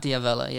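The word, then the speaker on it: D'Avella: Is that how you say his name? – Matt Diavela, yeah.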